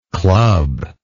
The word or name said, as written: clubbing